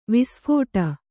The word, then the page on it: ವಿಸ್ಫುಟ: visphuṭa